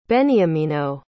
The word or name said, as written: beniamino